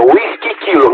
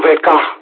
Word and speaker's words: Whisky Kilo: Whisky Kilo se pronunță, Whisky Kilo, nu WK.